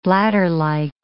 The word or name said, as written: bladderlike